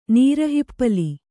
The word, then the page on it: ನೀರುಹಿಪ್ಪಲಿ: nīru hippali